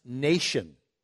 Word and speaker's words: Mnason: And some of the disciples from Caesarea went with us, bringing us to the house of, and it looks like Mnason is there in your Bible, but it's actually pronounced Nation, interestingly enough.